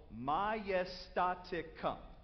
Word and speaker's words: majestaticum: Okay, it's five syllables. All right? It's called majestaticum.